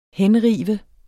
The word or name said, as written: henrive